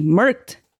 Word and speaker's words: Merkt: Anyway, so these people were Jack Elder, who was Catholic, and Stacy Merkt. I'm sorry, the last name. Merkt. – Surely it's pronounced differently? – I don't know. It's spelled M-A-R-K-T. Merkt. – M-A? – No, sorry, E-M-E-R-K-T.